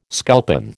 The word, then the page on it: sculpin: American English